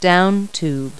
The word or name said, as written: down tube